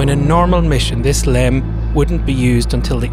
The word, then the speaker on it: LEM: There's the lunar module, Aquarius, spelled LM, which NASA pronounces as the LEM.